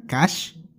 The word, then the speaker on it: Kaaix: No sé realmente qué significa la palabra cash, como está escrito con K-A-A-I-X.